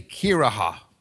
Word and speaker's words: kirihha: The English word church, that word descends from an old English word which is spelled C-I-R-I-C-E. That word was akin to an old German word that was spelled K-I-R-I-H-H-A, Kiraha. But both of those words derive from a late Greek word. Kuriakon, K-U-R-I-A-K-O-N, which comes from another Greek word, kuriakos, which is the possessive form of the word kurios, which is the term for Lord.